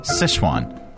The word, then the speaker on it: Sichuan: Sichuan. Sichuan.